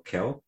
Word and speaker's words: Khill: Am I pronouncing it right? It is just K-H-I-L-L, Keel.